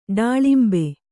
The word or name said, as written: ಡಾಳಿಂಬ